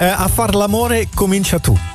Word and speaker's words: A far l'amore comincia tu: A far l'amore comincia tu.